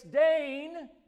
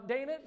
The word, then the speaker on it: Dane: And I'm going to tell you, for the entire time we lived there, she'd come out, she'd say my name, and she'd always say it wrong, and I'd say, it's Dane. And then 5 minutes later, she'd say, now, Dana, she said, I said, it's Dane.